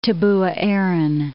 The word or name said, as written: Tabuaeran